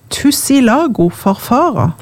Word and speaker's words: Tussilago farfara: Tussilago farfara? – Ja. – Er det sånn det uttales? – Ja.